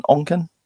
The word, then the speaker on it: Onken: Have I said that right? Onken? Onken? Yeah, that's right.